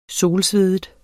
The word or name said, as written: solsveden